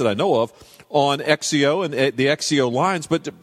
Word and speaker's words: XXIO: The basics of the brand are the Sexio brand, pronounced Sexio. You pronounce it like it was Z-E-K-S-I-O. – Sexio. But it's spelled, okay. – Yep. Yeah, Sexio, but it's spelled X-X-I-O.